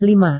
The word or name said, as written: lima